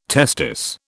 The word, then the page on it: testis: British English